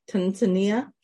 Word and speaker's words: Tanzania: I won't be able to say it correctly. Tanzania? – Tanzania. – Tanzania. – Uh-huh. That's how you say it.